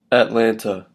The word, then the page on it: Atlanta: American English